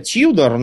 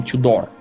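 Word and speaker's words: Tudor: Вообще-то англичане говорят Тьюдор, но тогда было принято, и сейчас в России тоже мы говорим Тюдор, потому что это на французский манер, да, произносится.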